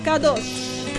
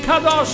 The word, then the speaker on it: Kadosh: Si legge con l'accento sulla O, Kadosh. – Kadosh.